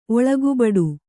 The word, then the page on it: ಒಳಗುಬಡು: kannada